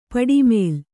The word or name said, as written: ಪಡಮೂಲೆ